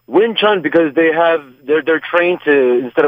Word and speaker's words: Wing Chun: It's called Wing Chun.